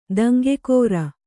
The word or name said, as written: ದಂಗೆಗಾರ